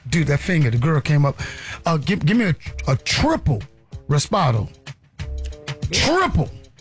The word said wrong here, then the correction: Reposado: I said, Reposado.